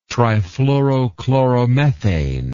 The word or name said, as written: trifluorochloromethane